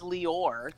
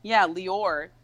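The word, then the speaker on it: Leor: So my middle name is Lior. – Lior? – Lior.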